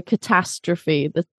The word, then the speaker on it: catastrophe: So catastrophe, the ta is the stress in that word.